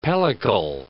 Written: pellicle

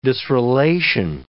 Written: disrelation

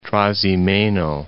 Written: Trasimeno, Lake

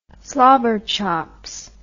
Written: slobberchops

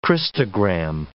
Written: Christogram

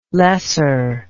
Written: loesser

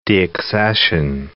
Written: deaccession

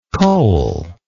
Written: coalless

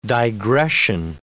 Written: digression

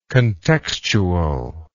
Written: contextual

